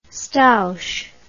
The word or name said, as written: stouch